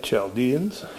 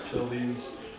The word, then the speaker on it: Chaldeans: Chaldeans. Chaldeans